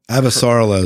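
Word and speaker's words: Avasarala: Avasarla is the way they pronounce it in the audiobook.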